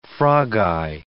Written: frogeye

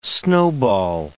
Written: snowball

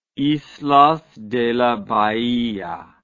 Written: islas de la bahia

